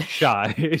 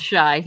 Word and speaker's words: Shaj: Uh, number three, this is where I am putting the original Albanian language version of Fall from the Sky, Shaj. Shaj. Uh, shy, shy, I believe is how it should be pronounced. Shy, yeah. – Uh, it's shy, yes.